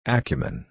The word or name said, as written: acumen